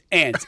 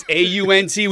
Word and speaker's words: aunt: It's ant. A U N T. We pronounce that A N T. – Well, hold on. You think aunt? I think aunt sounds more white.